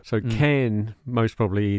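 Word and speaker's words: Càrn: did he pronounce it Cairn? Either way, it's spelt C-A with an accent, R-N. Cairn, Cairn, Cairn. I think it was Cairn.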